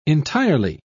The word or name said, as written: entirely